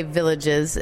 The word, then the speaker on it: Villages: You also brought here for us to taste the Beaujolais Nouveau Village, and it's spelled just like we would say villages in America, folks, if you're seeing that, but village is our French pronunciation.